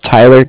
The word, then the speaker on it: Tyler: My name is Tyler, T-Y-L-E-R. I'm calling from Cardiff. It's spelled C-A-R-D-I-F-F as in Frank Frank.